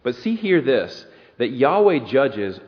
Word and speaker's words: Yahweh: This is the name of God, which is four letters in Hebrew that are probably pronounced Yahweh.